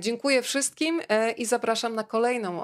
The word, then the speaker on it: Dziękuję: Dziękuję.